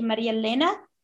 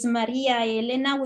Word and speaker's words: Maria Elena: And so that's how I say my name, because in Italian is Maria Elena, which is three conjoined vowels that don't merge together.